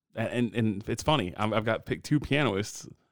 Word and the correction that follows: pianist: I believe it's pronounced pianist.